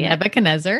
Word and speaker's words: Nebuchadnezzar: So Daniel starts off with King, I'm going to butcher his name, you say Nebuchadnezzar. – It. King Nebuchadnezzar is reigning.